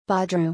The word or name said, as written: Badruu